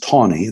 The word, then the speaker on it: Taney: When Justice Taney, Chief Justice Taney, that's how you pronounce his name, said that Africans had never been, never could be, were never recognized as citizens of the United States.